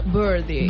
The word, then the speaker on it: birdie: Perquè has de pronunciar així a l'anglesa, birdie.